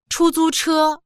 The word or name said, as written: chu1zu1che1